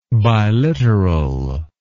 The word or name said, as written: biliteral